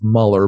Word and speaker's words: Mueller: He's actually pronounced Muller, but spelled Mueller.